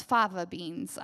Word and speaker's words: fava beans: The other thing is, I said it wrong at the last service, you guys, I called it flava beans, like flavor. No, it's fava beans.